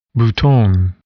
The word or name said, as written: bouton